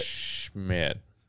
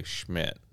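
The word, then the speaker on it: Schmitt: Vote for the one that rhymes with Lit. Schmidt, a.k.a. Schmidt.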